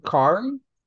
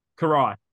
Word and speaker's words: Karai: Is it Kairi? – Karai. – Karai. Okay, correct.